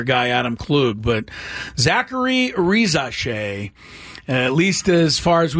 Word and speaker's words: Risacher: But it's looking like Rizache. Re, which is the R-I part. Za, S-A. And then C-H-E-R is pronounced Shay.